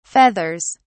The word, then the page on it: feathers: British English